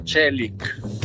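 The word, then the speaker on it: Çelik: Si pronuncia Celic, no Celic, Celic, che significa acciaio.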